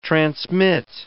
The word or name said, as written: transmit